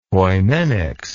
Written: poimenics

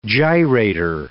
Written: gyrator

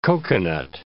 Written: coconut

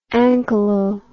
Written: anchylo

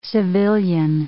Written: civilian

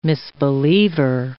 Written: misbeliever

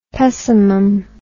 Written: pessimum

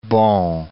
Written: Bon, Cape

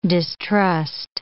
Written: distrust

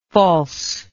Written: falseness

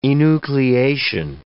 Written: enucleation

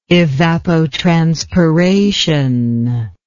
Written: evapotranspire

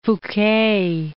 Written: Fouque

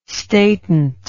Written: statant

